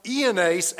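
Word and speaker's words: Iannes: Strong says their names are pronounced Ianace and Iambres. I'm just not going to say that, okay? It starts with a J.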